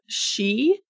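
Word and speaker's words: sídhe: And these are like not spelled at all how they're pronounced. I did some very careful like looking up the pronunciation to figure this out. But she or fairy.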